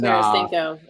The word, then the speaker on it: Tarasenko: And Tarasenko. Let's say it right. Say it right.